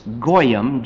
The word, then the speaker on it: goyim: The word Gentile actually means, from the Hebrew, is goyim, G-O-Y-I-M. Goyim, I guess, would be the proper pronunciation.